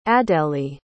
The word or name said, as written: adélie